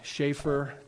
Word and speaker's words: Chafer: The founder of Dallas Seminary, Lewis Sperry, some pronounce his last name Chafer, C-H-A-F-E-R.